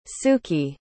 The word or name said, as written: sukey